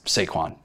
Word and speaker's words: Saquon: We're not a pronunciation podcast, although it's Saquon, but...